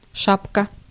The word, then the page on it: hat: /hæt/